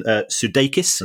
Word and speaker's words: Sudeikis: But certainly, the people involved at Ted Lasso are doing very well, because apparently Jason Sudeikis, uh-huh.